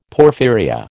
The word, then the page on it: porphyria: American English